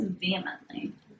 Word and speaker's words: vehemently: I think it's vehemently. – You don't pronounce the H hard? – No.